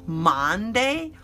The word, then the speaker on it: Monday: Capital M-O-N-D-A-Y. Oh yeah, it looks like Monday, but it's not pronounced that way. No, no, my friend. It is pronounced Monday. Just like Sunday. Sunday is S-U-N, but Monday is M-O-N. Why do we pronounce the O and the U exactly the same?